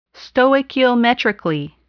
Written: stoichiometrically

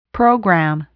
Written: program

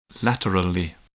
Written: laterally

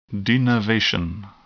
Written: denervation

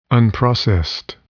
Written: unprocessed

